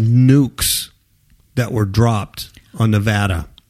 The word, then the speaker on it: Nevada: Nevada. – Nevada. That's the correct pronunciation. That's the way the locals say.